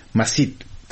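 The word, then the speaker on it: Mehsud: از شبه که در برخی مناطق خیبر پشتونخوا و همچنان در برخی بخشای افغانستان مثل خوست حرف واو به شکل یا معمولا تلفظ میشه. به همین دلیل مسعود که بعضی از رهبران تحریک طالبان پاکستان تخلص میکنن مسید تلفظ میشه و به همین ترتیب پشتون پشتین تلفظ میشه.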